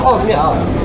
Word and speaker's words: 耗子面: OK，这是中卫的耗子面啊。 – 耗子面，本地人应该怎么读？ – 耗子面。